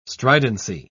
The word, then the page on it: stridency: British English